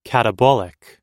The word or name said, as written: catabolic